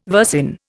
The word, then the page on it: versin: Indian